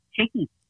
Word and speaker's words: Chickie: Chickie. – Hickey? – Chickie, like Chick, like Chickie.